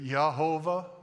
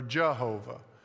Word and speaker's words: Yehovah: Depending on the vowel I put between the first and the second letter, it could say Yah, or it could say Jah. So you get Yehovah or Jehovah.